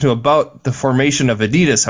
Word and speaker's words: Adidas: It's actually pronounced Adidas, apparently. – Okay. – Is what she told me when she listened to that. That the company's not Adidas. We always call it Adidas, but it's not.